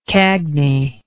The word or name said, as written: cagney